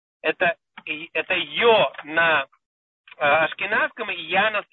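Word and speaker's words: Йо (יה): Если написано буква Юд и Гей, это читается как Йо, да, или Я.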